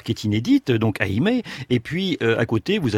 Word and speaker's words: Eymet: Votre tour de France à vous ce matin, nouvelle étape entre Eymet et Pau.